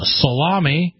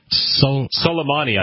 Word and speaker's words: Soleimani: States on Twitter that he is concerned that more people were reading the Babylon Bee's satire about the death of terrorist Qassam Soleimani. That cannot be the way. I am obviously mispronouncing. – Soleimani, I thought that's what it was. – There you go. I'm going with yours.